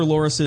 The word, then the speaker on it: loris: They were hiding two slender lorises. Is that how you pronounce it? They're like little lemurs, like little monkeys. – Loris? – L-O-R-I-S.